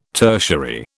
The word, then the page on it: tertiary: British English